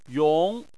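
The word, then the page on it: yong: tone 2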